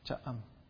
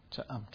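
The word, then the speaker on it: Cha-am: Cha'am. Two syllables. Cha'am.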